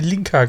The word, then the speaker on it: Linka: In, der englischen Originalfassung wird sie Linka geschrieben und ausgesprochen.